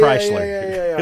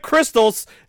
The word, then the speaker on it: Kreischer: Yeah, Bert Kreischler. Yeah. Okay. Everyone always says his name wrong. So even on like TBA, he's on the Go Big Show, and the announcers are like, Brent Crystals.